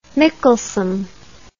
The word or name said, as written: Mickelson